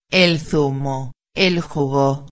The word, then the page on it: el zumo, el jugo: espanhol